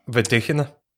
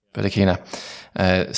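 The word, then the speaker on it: Vedekhina: How do you say her name again? – Vedkina. – Yeah, Vedkina.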